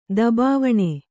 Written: ದಬಾವಣೆ

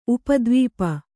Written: ಉಪದ್ವೀಪ

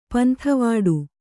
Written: ಪಂತಿವಿಡಿ

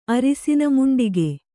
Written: ಅರಿಸಿನಮುಂಡಿಗೆ

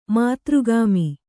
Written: ಮಾತೃಗಾಮಿ

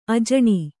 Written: ಅಜನಿ

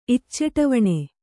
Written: ಇಚ್ಚಟವಣೆ

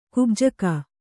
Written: ಕುಬ್ಜಕ

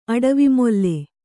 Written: ಅಡವಿಮೊಲ್ಲೆ

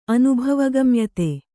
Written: ಅನುಭವಗಮ್ಯತೆ

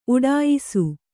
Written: ಉಟಾಯಿಸು

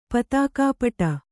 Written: ಪತಾಕಾಪಟ

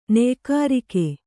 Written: ನಯಗಾರಿಕೆ

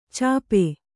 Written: ಛಪ್ಪೆ

